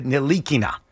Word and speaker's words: Ntilikina: When he got drafted, I heard him pronounce his name. – And what did he say? – He did not pronounce it that way. He said Ntilikina.